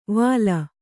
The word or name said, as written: ವಲ್ಲಿ